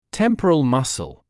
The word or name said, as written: temporal muscle